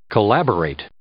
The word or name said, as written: collaborate